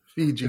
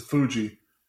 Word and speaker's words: Fuji: Fiji. – It's Fuji.